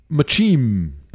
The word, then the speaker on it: muchim: All right, let's move on to raw Brussels sprout machim. I believe is how you pronounce it. – Yes.